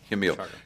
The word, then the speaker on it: Jamil: Yeah, Jamil. Because in Spanish, the J is pronounced like an H, and if I'm getting that wrong, at me in the comments.